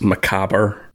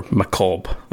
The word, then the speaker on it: macabre: For British English and for French, we would pronounce it as macabre, obviously with a more of a French accent. But I know American English is more macabre, which I think is more, I think it's more entertaining to say it that way.